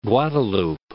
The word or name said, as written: Guadeloupe